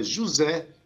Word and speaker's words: Juze: Esse rei é com H-E-Y. E o artista se chama José, se escreve J-U-Z-E.